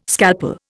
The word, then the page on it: scalpel: Indian